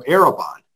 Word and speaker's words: Arrabon: Bailey, Executive Director of Arabon, which is spelled A-R-R-A-B-O-N. The word Arabon means a foretaste of what is to come.